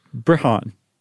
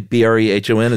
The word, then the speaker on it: Brehon: How is it pronounced? – Brehon. – Okay, Brehon, is that what you're going to go with? – Yeah, it rhymes with Breton.